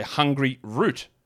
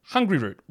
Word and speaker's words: Hungryroot: Now, they've given me a pronunciation guide here to make sure I don't say Hungry Root. Just call it one word, Hungry Root.